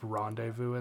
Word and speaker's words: rendezvous: How many words you mispronounce that are just basic English, but then you just like fucking drop rendezvous in there like a pro. Because rendezvous is spelled like an insane person decided how it was spelled, and you're just so quick on it.